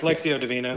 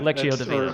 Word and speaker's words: Lectio Divina: Well, people say it different ways. Lectio Divina. – Lectio Divina.